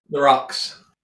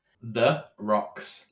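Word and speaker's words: Therox: The Rocks. – Sorry, how do you pronounce that? – The Rocks. You know, like you have a drink on the rocks.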